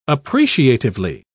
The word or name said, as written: appreciatively